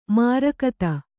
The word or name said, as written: ಮಾರಿಗುತ್ತ